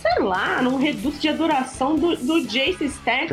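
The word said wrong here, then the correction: Stacee Jaxx: Stacy Jax. É, Stacy Jax, a gente trocando a sílabas toda.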